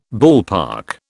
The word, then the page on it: ballpark: British English